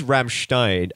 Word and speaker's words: Rammstein: It is Rammstein. And you can always tell in German, with an IE and an EI, what it is, because you pronounce the last letter.